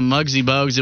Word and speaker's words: Muggsy Bogues: And I, instead of Muggsy Bogues, it was Musky Bogues for like 15 minutes.